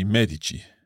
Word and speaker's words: medici: That would be medici. – Medici, because the emphasis is on the first syllable, on the syllable me.